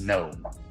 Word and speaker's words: GNOME: The G is silent. It is GNOME.